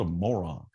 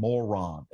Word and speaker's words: moron: He'll do that Southern thing where you get both syllables and make sure both of them hit on moron. It's like it's not moron, it's moron.